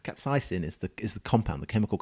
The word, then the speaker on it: capsaicin: So the thing that, the compound that that you're tasting that kind of hot flavor is a predominantly a compound called capsaicin, right? Mm-hmm. Which is a kind of compound which you is know, that how you say it? – Yeah, capsaicin.